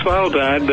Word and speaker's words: Svalbard: How do you pronounce that? – Well, actually, the Norwegians call it Svalbard, but I think it's better known as Spitsbergen in the English-speaking world.